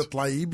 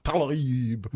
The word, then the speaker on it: Tlaib: It's your pronunciation of Rashida Tlaib that leaves me a little bit green. – Rashida Tlaib, you mean? – Tlaib. I feel like a toad. Tlaib.